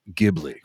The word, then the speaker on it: Ghibli: This just in, it is pronounced Ghibli.